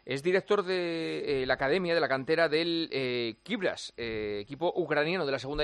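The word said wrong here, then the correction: Kryvbas: Creo que no he pronunciado bien el nombre del club, ¿no? ¿Cómo se llama? – Bueno, a ver, yo tampoco. Se llama Kribas.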